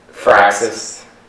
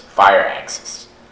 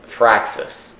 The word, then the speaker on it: Firaxis: I think Firaxis. I don't know. Firaxis sounds like someone with an axis. – It is spelled F-I-R-A-X-I-S. – So it's not fire. It's definitely not fire axis.